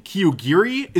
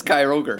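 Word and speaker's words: Kyogre: And then you're trying to prevent the other team from summoning the like apocalyptic legendary Pokemon in Kyogiri. Is that what we're going with pronunciation? – Kyogiri, what? – Kyogiri? – Oh, Kyro. It's Kyroger. – Kyogiri is how I used to pronounce it, but I thought you gave a shit about that.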